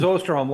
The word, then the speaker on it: Osterholm: Yep, it is Osterholm, long O.